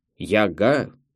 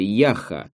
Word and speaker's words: Яха: «Кстати, что это за название у твоего фильма такое чудное?» «Яга». – Он смешно произносит это слово с ударением на второй слог. – «Яга?» переспрашивает Рашид. – «Да нет, фильм называется «Яха».